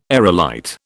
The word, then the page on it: aerolite: British English